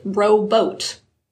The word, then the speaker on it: robot: And that is, the pronunciation of the word robot wasn't standardized until the 1960s, which meant that many people up until then pronounced it rowboat.